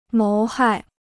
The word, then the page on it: 谋害: móu hài